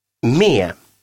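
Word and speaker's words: med: «Ме» – «с». «Ме». Для придания некого шарма можно, конечно, добавить «д» в произношение, но это совершенно не обязательно.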